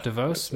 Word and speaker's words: DeVos: DeVos, Mr. Ben DeVos, thank you for coming on The JDO Show.